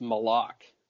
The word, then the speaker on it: Malak: I think it's got to be pronounced Malak, just like Palat, right? M-A-L-A-K.